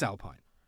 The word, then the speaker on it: Alpine: If you want to know the correct way of pronouncing the brand Alpine, listen to a previous podcast of ours, because I completely clear up any uncertainty at all. It's Alpine. But I don't mind if you want to refer to it and sound silly by saying Alpine.